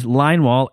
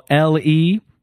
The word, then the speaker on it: Leinwohl: My last name is Leinwohl, Leinwohl, Leinwohl, like a line on the wall.